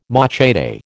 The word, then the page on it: machete: American English